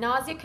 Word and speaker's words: Nazia: And Nazia Khatoon, let me spell it for you. N-A-Z-I-A, first name. K-H-A-T-U-N.